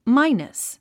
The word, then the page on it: minus: mǽinəs; マイナス